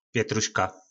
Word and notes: petruska: po nasimu

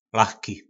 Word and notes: lachki: po našimu

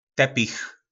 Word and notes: Tepich: po nasimu

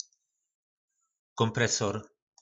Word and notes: kompresor: po našimu

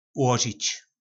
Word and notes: ulozic: po nasimu

